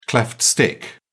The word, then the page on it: cleft stick: British English